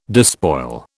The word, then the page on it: despoil: British English